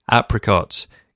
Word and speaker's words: apricot: So one of your answers, apricot, or as Carly Simon pronounces it, apricot.